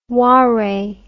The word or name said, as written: whare